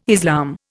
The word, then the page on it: Islam: Indian